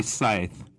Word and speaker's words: Scythe: Probably Scythe. – Thank you. – Scythe. Yeah, it's like the tool that you kill people with.